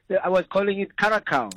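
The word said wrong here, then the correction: Curaçao: Oh, Curacao, yeah.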